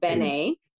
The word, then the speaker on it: Bene: So his name is Bene. So it's spelled B-E-N-E.